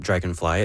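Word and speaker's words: Draganfly: Well, first of all, the name Dragonfly, it sounds like the insect, but it's spelled D-R-A-G-A-N, fly, for context.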